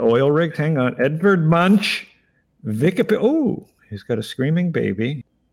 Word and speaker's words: Munch: His name is Munch, M-U-N-C-H, Munch, it's pronounced in English.